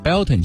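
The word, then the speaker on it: Beltane: 其实它在英文当中呢是念作 Belton 节，在古凯尔特语当中呢 Belton 被称为五月，而火呢又是节日当中不可或缺的元素，因此呢这个 Belton 节也被翻译成为火把节或者是五月节。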